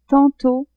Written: tantot

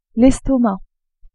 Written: estomac

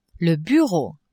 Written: bureau

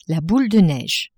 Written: boule de neige

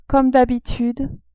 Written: comme d'habitude

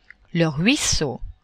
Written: ruisseau

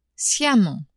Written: sciemment

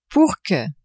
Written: pour que

